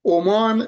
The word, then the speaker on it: عمان: بله من در فقط در حیطه تخصصم یه جمله بجم در باره تلفظ عمان، اما نیست تلفظ دولت کشور عمان بدون تشدید میمه،